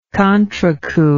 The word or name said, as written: contrecoup